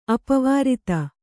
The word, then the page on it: ಅಪವಾರಿತ: apavārita